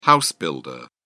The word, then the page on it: house-builder: British English